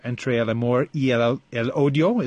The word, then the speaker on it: Entre el amor y el odio: And I'm going to take a run at the pronunciation here. Entre el amor y el odio, is that right? – Yes.